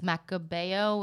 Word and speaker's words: Macabeo: I guess it's the same as Macabeo, which is what, you know, in Spain and everywhere else calls it, you know, Macabeo.